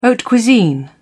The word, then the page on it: haute cuisine: British English